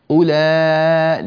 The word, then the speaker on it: أولئك: أولئك. – لا لا يا إدريس، أولى مش أولئك، ما فيش واو يا ابني، هذه الواو لا تنطق. أولئك.